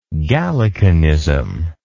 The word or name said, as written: Gallicanist